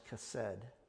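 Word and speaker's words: chesed (חֶסֶד): And she blesses them by using a beautiful Hebrew word here, pronounced chesed.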